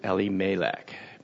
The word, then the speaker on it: Elimelech: Traditionally, in our Western culture, we pronounce his name Elimelech. But for a Hebrew-speaking person, they would pronounce it Elimelech, because they put the emphasis on the first two letters, which is El, which is the name for God.